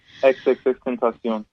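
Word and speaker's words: XXXTentacion: and Extension. Is that how you say it? – XXX Tentacion. Yeah.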